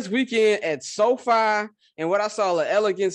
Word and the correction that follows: Allegiant: Stadium, is that how you pronounce it? Elegant? – Allegiant Stadium. – That boy said elegant.